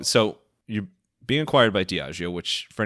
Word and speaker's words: Diageo: Diageo? – You are. – Perfect. – Are. – Cool. – You pronounce all right. – It perfectly. Diageo.